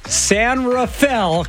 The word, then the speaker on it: San Rafael: Denise is listening and donating from San Rafael, California. – Hey! You did it! – Yay! – Did it! – Yes! – Yeah! You said it right!